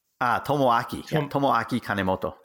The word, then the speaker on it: Tomoaki Kanemoto: One of the players that I was fascinated as I read your site, and you're going to have to help me when I butcher this name, is Tomokiya Kanemoto. – Tomoaki Kanemoto. Yeah.